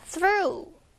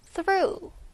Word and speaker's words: through: 자, through. 요게 처음 듣는 전치사일 수 있어요. 잘 들으세요. through. th 발음. through. through. through this experience.